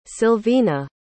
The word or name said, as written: Sylvina